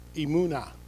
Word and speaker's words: emunah: In the Old Testament, the Hebrew for trust or believe is, let me pronounce this right, emunah, right? Sounds like Klingon, right? Emunah.